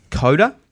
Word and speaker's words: Koda: Coda. – Coda. – 3. – Yeah. – Okay. How do we spell that? – K-O-D-A. – K-O-D-A.